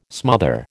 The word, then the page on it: smother: American English